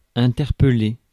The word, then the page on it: interpeller: France, Paris; /ɛ̃.tɛʁ.pə.le/